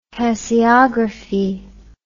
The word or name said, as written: tasseography